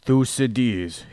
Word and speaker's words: Thucydides: He used a quote from Galen in regards to Thucydides' history, whatever that means.